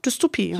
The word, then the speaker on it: Dystopie: Wir leiten erstmal ganz in Ruhe ein und zwar handelt es hierbei sich um eine Dystopie, habe ich es richtig ausgesprochen? – Ja, hättest du auch so ausgesprochen, Dystopie.